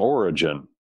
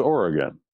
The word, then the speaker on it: Origen: People call him Origen. Origen. He was a church father. Well, that's not the way they pronounced it back then. There was no soft G. It was Oregon.